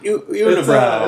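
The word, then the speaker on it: Unibroue: And I'll be drinking a beer by, I think I'm saying the name right, Unibrow. It's UNIBROUWE. What's that W? – Unibrow.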